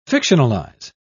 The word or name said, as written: fictionalize